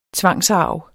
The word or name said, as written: tvangsarv